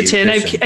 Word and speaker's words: quercetin: Quercetin.